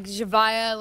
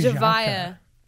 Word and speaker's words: Javaya: I say like... Okay, it's like Javaya, like very Jaka. Like the J. – Very Javaya.